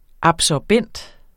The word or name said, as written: absorbent